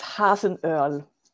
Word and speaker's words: Hasenöhrl: It's Hasenöhrl. And you can't pronounce Hasenöhrl. The last part like Earl of Grey, the T. Then it's always right.